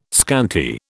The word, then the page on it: scanty: British English